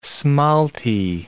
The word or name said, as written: smalti